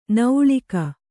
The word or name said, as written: ನೌಳಿಕ